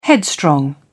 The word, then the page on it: headstrong: British English